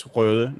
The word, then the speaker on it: Rode: Correctly pronounced, it is Rode, not not Rode.